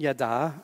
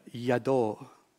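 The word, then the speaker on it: yadah: Now, this word isn't pronounced like it looks. This isn't yada, as in yada sells Avon. This is pronounced yado.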